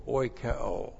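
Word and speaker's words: oikeō: That's O-I-K-E, long O, oikeo.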